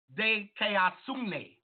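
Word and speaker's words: dikaiosune: So righteousness is pronounced like this. This is righteousness. Deasune. Dekeasune. Dekeasune. And this is simply mean, watch this, the doctrine concerning the way in which man attain a state of approval, approved of God.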